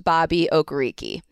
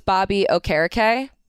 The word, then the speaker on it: Okereke: Also something that I found out at camp, it is not pronounced Bobby Okereke. He is going with a more traditional Nigerian pronunciation. So it's Bobby Okereke. – Okereke.